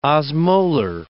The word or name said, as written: osmolar